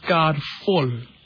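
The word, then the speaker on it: Karvol: I think, sorry, Mulana, it's pronounced Kavel.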